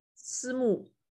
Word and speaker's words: Simu: I just don't know how to say his first name? I've never heard his first name pronounced. – Simu. – Simu? – Yeah, Liu. – Because it's S-I-M-I, right? – S-I-M-U. – S-I-M-U is his first name?